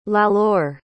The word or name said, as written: Lalor